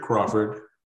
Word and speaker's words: Crawford: Richard Crawford, C-R-A-W-F-O-R-D, address 302 North Washington Street, Orwigsburg, Pennsylvania.